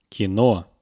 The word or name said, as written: kino